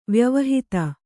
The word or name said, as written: ವ್ಯವಹಿತ